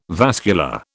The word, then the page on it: vascular: British English